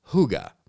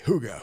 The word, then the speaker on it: hygge: You spell it H Y G G E, but according to Wikipedia, you have to pronounce it as uh, Hygge. Uh, almost sounds like a from a, from a song in uh, Guardians of the Galaxy. Hygge, Hygge.